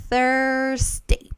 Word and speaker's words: Thursday: Entonces, esta es la pronunciación de TH. Thursday. Primero va a ser este sonido, th, y después viene la R.